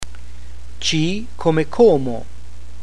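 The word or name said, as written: C come Como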